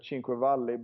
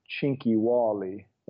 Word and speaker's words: Cinquevalli: So at first it was uh, Cinquevalli, but then he in 1893 he became British actually, and he was very proud of of that, and he really tried to become British. So he would actually pronounce his name Chinky Wally, like a British way for Chinky Wally.